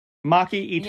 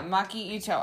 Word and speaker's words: Maki Itoh: Maki Itoh. – Yeah, Maki Itoh.